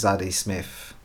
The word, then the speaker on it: Zadie Smith: I'm like, wow, because this is Zadie Smith.